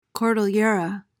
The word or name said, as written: cordillera